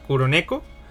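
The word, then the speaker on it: Kuroneko (96猫): Eh, se pronuncia Kuroneko.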